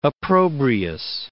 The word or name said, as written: opprobrious